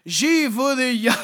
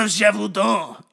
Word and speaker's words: Gévaudan: I had to look up how this was pronounced because I would have pronounced it like Gévaudan. I would have pronounced it stupidly.